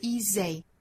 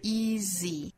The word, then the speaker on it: easy: 还要注意一个发音，就是 easy 这个词其实两个元音都是相同的口型，不是 easy, 而是 easy, easy。